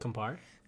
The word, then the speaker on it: Campari: So it's 2 ounces of vodka and 3 1/4 ounces of, what is it, Campari? Is that how you say it? – Campari.